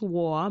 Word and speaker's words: Waugh: And they said, no, they tried to pronounce my last name, which is War, but when you look at it, it's a little difficult. If you aren't familiar with the English author per se, Evelyn Waugh.